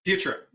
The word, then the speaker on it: Futurum: Futurum, right on.